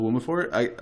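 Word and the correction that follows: Dwumfour: Michael Doomfor. – Yeah, I know. Doomfor. I don't know why I wanted to say Wumafor.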